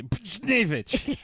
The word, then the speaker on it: Buchnevich: On Monday night, Tom Wilson punched Rangers forward Pavel Buchnevich. – I can never say his name. – It sounds like you're holding in a sneeze. Buchnevich.